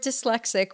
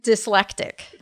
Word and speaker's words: dyslexic: So David Miscavige mispronounces the word dyslexic, which is too bad because he has to say it a lot.